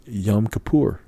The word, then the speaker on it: Yom Kippur: What's the proper pronunciation? Correct. – It's Yom Kippur.